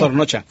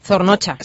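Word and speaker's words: Zornotza: A ver si lo digo bien. – Zornocha. – Zornocha. – Sí.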